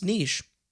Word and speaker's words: niche: I actually recently learned it's pronounced niche.